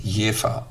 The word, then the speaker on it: Jever: Die korrekte Aussprache lautet Jever, also mit einem scharfen F und nicht nicht mit einem weichen V.